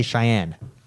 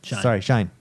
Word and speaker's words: Shine: And I always, the way it's spelt, my dyslexia wants me to say Cheyenne. – I can see that. You know? Yeah. – But, Shine.